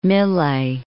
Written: Millais

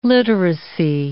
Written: literacy

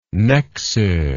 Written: Nexo

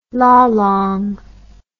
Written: lalang

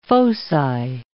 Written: foci

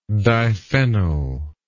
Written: diphenyl

